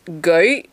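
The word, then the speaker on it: gøy: G-O. It's the O with a slash through it. Y. Ooh. And it's pronounced goy.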